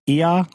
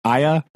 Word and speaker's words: IAH: Another one that I really liked, I think it's pronounced Ia or Aya. It's IAH.